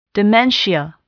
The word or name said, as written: dementia